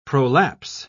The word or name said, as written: prolapse